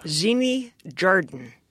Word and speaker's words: Xeni Jardin: Xeni Jardin. – Great pronunciation. – I think it's pronounced... – Your own name.